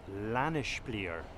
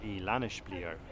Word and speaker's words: Lanespeare: And the first one is called Lanishblier, and that is spelt Lane, L-A-N-E, S-P-E-A-R-E, Lanishblier, and Swedish IKEA product.